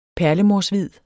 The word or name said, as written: perlemorshvid